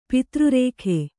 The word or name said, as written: ಪತ್ರರೇಖೆ